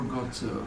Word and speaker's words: Gozo: Do you actually pronounce it Gozo or Gozo? – Gozo.